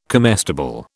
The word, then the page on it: comestible: British English